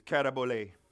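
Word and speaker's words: katabole (καταβολή): See, the word foundation is in Greek is katabole. That's how you pronounce that.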